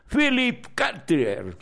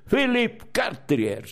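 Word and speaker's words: Philippe Cartier: Philip Cartier, se pronuncia. Philip Cartier, sí, en francés, sí.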